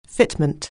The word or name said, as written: fitment